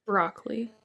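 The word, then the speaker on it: Brauchle: It's B-R-A-U, bra, and then C-H-L-E. Brockley? – Broccoli.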